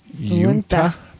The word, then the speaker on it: Uinta: But anyways, so this is brewed by the UNTA. Is that how you pronounce that? UINTA. Yunta Brewing Company.